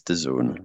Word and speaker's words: DAZN: Is it DAZN? – Pronounced one thing correctly. Challenge. Impossible. – DAZN. – And the answer is no. No, I've got more important things. – As in, I'm in DAZN. Is that what that is? – It's DAZN.